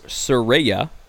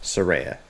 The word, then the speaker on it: Saraya: So, Saraya, as it's pronounced. – Saraya, yes.